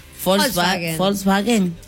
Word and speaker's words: Volkswagen: Sí, algunos dicen Volkswagen. – Pronunciación correcta en alemán. – Volkswagen.